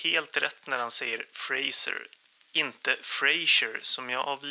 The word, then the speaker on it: Fraser: Jag har så här ett par veckor efter inspelning konstaterat att man mycket riktigt uttalar Brendan Frasers efternamn just Fraser och inte Frasier som jag tror att jag säger i merparten av det här avsnittet.